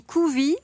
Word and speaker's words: Koovee: On dit Kouvi, mais ça s'écrit K-O-O-V-E-E.